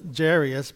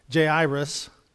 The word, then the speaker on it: Jairus: Jesus tells I always pronounce it Jairus, but it's not. It's Jairus.